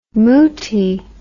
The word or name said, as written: mootie